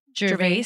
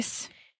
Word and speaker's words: Gervais: And Ricky Gervais, that's how you say his name, right? – Gervais. Gervais was hosting, who I love because I love the original Office.